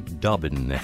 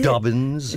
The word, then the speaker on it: Dobbin: Both of us waving a big hello to Charlie Dobbin.